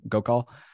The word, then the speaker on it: Gokal: Solana was created by Solana Labs, founded in 2018 by Anatoly Yakovenko and Raj Gork. How you pronounce Raj's last name, Gokal.